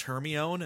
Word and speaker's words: Hermione: And I remember being told that her name was pronounced Hermione.